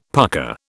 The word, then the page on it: pucker: British English